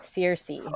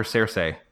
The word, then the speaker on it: Cersei: They mispronounced another. They said like something else. Cersei. I think they called it Cersei. – Oh yeah. Yeah. They called her Cersei. Like David Benioff said like Cersei, and then D.B.